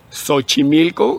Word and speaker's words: Xochimilco: Tienes... Xochimilco, que es una especie de S, pero luego sí.